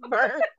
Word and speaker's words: Byrne: This is taking a long time to pronounce burn.